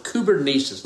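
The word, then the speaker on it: kubernesis (κυβέρνησις): Now, that is a really, really important word, kubernesis. Let me spell it for you. K-U-B-E-R-N-E-S-I-S.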